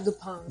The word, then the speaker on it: Dupin: Dupin. No sé si lo he pronunciado bien, ah, Dupin. yo diría Dupin.